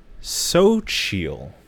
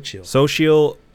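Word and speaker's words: Xochitl: So it is Sochil. – Sochil Gomez, who plays America Chavez. – That's not that hard. Sochil. – Sochil.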